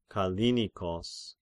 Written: Kallinikos

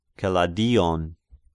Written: Keladion

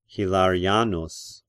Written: Hilarianus

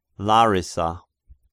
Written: Larissa

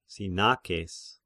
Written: Sinakes

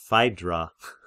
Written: Phaidra

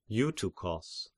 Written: Eutychos

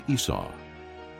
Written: Esau

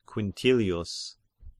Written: Quintilius

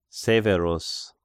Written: Severus

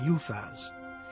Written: Uphaz

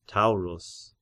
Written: Taurus

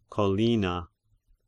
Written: Collina

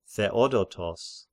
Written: Theodotos

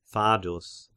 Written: Fadus

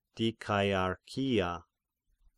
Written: Dikaiarchia

